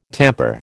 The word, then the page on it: tamper: American English